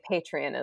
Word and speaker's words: Patreon: I, for a long time, was just calling it Patronus until my husband just kind of kept laughing at me. So yeah, I think Patreon is the right way to say it.